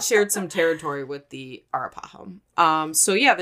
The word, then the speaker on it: Arapaho: Arapaho. – Arapaho, thank you. I was like, I know I'm like overpronouncing.